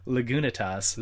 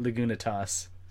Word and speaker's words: Lagunitas: And we were asking, how else would you pronounce Lagunitas? And he corrected us, and apparently... – Yeah, we were doing it wrong the whole time. – Yeah, apparently it's pronounced Lagunitas.